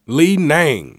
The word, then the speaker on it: Li Ning: Li Ning.